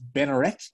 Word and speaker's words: Bednarek: It looks like Bednarek, but the D apparently is silent, so it's Benarek.